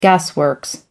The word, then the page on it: gasworks: American English